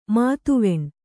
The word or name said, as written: ಮುದಿವೆಣ್